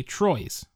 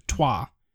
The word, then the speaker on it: Troyes: For the next game is I got a friend purchased me Troyes. It's spelled Troyes, T-R-O-Y-E-S, but it's actually pronounced Trois.